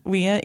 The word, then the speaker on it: Wien: Vienna, Austria is actually pronounced like Wien in Austria. And it's really Wien. Yeah, it's like very upsetting. They don't have the ending. They use a W instead of a V. – I know, but the W is pronounced like a V.